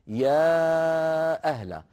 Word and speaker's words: يا أهل: إذن لاحظ يا أخي الكريم في الآية 19 في أولها قوله تعالى يا أهلا، هذه أصلها يا أهلا يعني بألفين، ألف يا وألف أهلا،